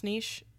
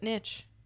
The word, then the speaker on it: niche: Is that how you pronounce niche? – That's how I pronounce it. – Sometimes people pronounce it niche. – Yeah, I think that's wrong. There's no T in there. Where's the T coming from?